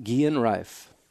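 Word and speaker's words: Guerin Rife: I've looked it up, and it's G-U-E-R-I-N. – Rife is another word, R-I-F-E, putter, but it's pronounced Guillen Rife.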